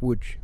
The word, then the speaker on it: Łódź: So the Polish football team is from the town of Łódź, which is looks like an L to us, but an L with a line through it. O D Z. – There is pronounced with a W.